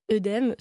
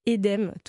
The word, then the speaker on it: œdème: Bah, la plupart des mots dans lesquels il y a un « e » dans l'eau, par exemple, suivi d'une consonne, comme par exemple le mot qu'on prononce tous et toutes, « œdème », se prononce en réalité « édème »,